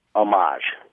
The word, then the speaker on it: homage: You would accent the second syllable if you're talking about the thing itself that you're doing, the tribute itself. This is an homage. – This is an homage to that other band.